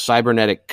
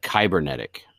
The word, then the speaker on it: cybernetic: He calls cybernetic kybernetic.